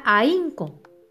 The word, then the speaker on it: ahínco: La palabra ahínco su bate de béisbol es el acento en la I. Y separada es a, in, co.